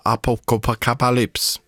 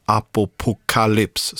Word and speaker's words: Apocalypse: Innan dess bandet som är lite halvkul att uttala, Apocalypse. Ja, nej, inte riktigt så, men Apocalypse, så ska man säga.